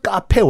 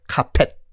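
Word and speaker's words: Capet: 카페도 스펠링이 C-A-P-E-T예요. 카펫. 근데 프랑스 발음 카페.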